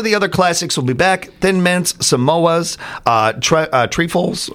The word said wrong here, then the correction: Trefoils: I don't know how you say it. Oh, Trefoils.